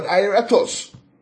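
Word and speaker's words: aoratos: A-O-R, A-O-R, A-T-O-S, Colossians 1. Yeah, right, Colossians 1. So, the word invisible, yeah, is the word ariatos.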